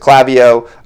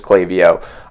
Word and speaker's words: Klaviyo: some people people pronounce it Klaviyo uh, which is how we originally pronounce it until we learn that the proper pronunciation is Klaviyo um, so one of the reasons we love Klaviyo is because you can create unique flows.